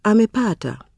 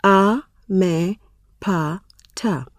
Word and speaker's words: amepata: For example, the word amepata has four syllables, a, me, pa, ta, and the stress falls on pa. The penultimate one, amepata.